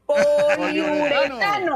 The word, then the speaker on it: poliuretano: Que tiene, este, en el corazón del esférico proporciona velocidad, precisión, consistencia, tiene speed shell, que es un cuero de poliute, ¿qué? – Poliuretano. poliuretano, poliuretano.